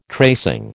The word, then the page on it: tracing: American English